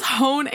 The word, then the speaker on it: Hoen: It was Hoen, H-O-E-N.